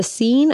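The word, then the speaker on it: Seine: It's spelled like CN, but it's not said like that. It's the scene.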